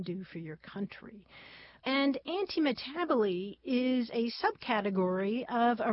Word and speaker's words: antimetabole: It's spelled A-N-T-I-M-E-T-A-B-O-L-E, antimetaboly.